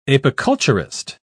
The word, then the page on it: apiculturist: British English